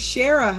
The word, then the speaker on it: Shara: I said that wrong. – Oh, you did it again. Shara.